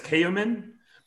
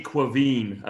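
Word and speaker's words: Caoimhín: So if I were not to look it up, I would pronounce it as Kayumin, but it's actually Quaveen. I looked it up. It's like Gaelic.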